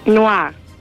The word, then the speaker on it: noir: Noir.